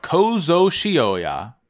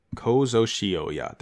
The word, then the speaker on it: Kozo Shioya: We are talking about Kozo Shioya. Shioya. Kozo Shioya. That's how you say it.